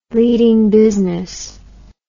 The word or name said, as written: leading business